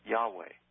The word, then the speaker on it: Yahweh: This particularly applies to using and pronouncing the divine name called the Tetragrammaton, which has the true pronunciation of something close to Yahweh.